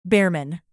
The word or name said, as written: birman